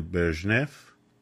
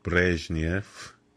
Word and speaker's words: برژنف: دکترین برژنف که البته تلفظ روسیش از برژنیف